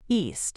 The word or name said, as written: East